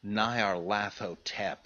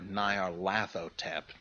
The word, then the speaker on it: Nyarlathotep: But, for instance, I think that the crawling chaos should be pronounced Nyarlathotep, or if you prefer, Nyarlathotep, which syllable you want to emphasize.